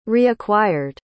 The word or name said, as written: reacquired